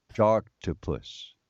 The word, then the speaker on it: Sharktopus: And you, very proudly, appeared in Shocktopus. – No, you must say it correctly. – Oh, sorry. – Sharktopus.